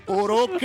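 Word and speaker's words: Örök: Oruk è, è la giusta pronuncia, infatti sei uno dei primi che la pronuncia correttamente. Uruk, sarebbe come scrivere.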